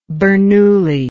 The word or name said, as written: Bernoulli